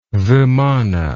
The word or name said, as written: vimana